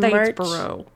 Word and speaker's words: Statesboro: But anyways, in Statesboro.